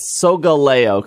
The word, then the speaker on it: Solgaleo: And then it's Solgaleo, because it's supposed to rhyme with Galileo.